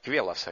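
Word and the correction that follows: Queloz: как я сказал. Он сказал, Келос, он француз.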